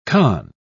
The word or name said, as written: conn